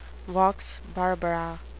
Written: vox barbara

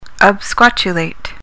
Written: absquatulate

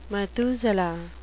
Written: methuselah

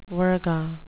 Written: virga

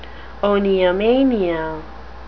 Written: oniomania

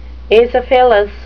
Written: acephalous

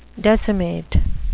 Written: decimate

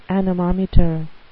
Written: anemometer

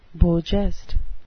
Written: beau geste

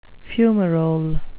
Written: fumarole

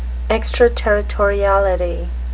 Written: extraterritoriality

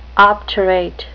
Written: obturate

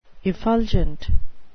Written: effulgent

